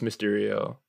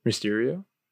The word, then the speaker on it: Mysterio: You said Mr. EO. – Mysterio? – Oh, I heard Mr. EO. – Oh, okay. Mysterio.